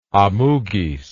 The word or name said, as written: amugis